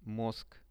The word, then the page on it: mozg: Russian